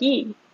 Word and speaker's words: Lee: It's either Lee or E, and I think in Korea it's technically E, but of course they put an L in front of, you know, Lee in Romanized words, so...